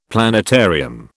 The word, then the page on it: planetarium: British English